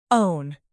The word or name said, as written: own